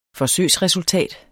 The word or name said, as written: forsøgsresultat